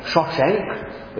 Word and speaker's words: Shishak: My wrath shall not be poured out on Jerusalem by the hand of Shishak. The Pharaoh Shishak, the Egyptian pronunciation is more like Shoshenk, but you know how it goes when you take a word from one language into another.